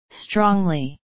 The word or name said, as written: strongly